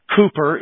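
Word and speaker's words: Cowper: In our bulletin this morning, I put a quotation from William Cooper, is how you pronounce his name.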